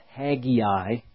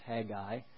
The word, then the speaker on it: Haggai: You'll see in Haggai, some people call it Haggai, but actually there's just Haggai, those two syllables.